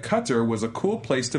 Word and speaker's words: Qatar: No, it's not guitar. – Some people say guitar, but they actually said that's very far removed from being the right way to say it. So it's Qatar.